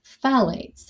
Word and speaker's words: phthalates: But what I'd like to start with is phthalates, and this is spelled P-H-T-H-A-L-A-T-E-S.